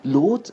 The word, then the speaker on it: Lot: das zum Bandnamen zu machen und es wird ausgesprochen wie das Werkzeug, wie das Lot.